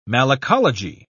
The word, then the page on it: malacology: British English